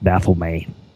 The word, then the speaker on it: Baphomet: Is that how you pronounce it? – As far as I know, Baphomet, and my wild friends from Windermere, Pennsylvania, Lucas, Don Reese, and others, Mike Corsi, James Cabetta, have all read the Satanic Bible by Antoine Sanzor-Lavey and said that that is pronounced Baphomet.